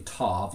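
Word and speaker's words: tov (טוב): It's pronounced actually tov, looks like T-O-V, and literally means good or goodness.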